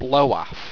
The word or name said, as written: bloaf